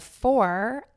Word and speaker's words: Fohr: James is the founder and CEO of Four, F-O-H-R.